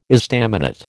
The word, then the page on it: estaminet: American English